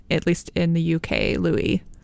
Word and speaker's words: Louis: Yes, they made it pretty clear in their press releases that it was Louis and not Louis. It's the French pronunciation.